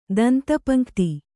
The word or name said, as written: ದಂತಪಂಕ್ತಿ